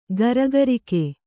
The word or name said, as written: ಗುರಿಗಾರಿಕೆ